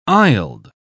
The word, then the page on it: aisled: British English